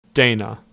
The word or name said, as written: Dana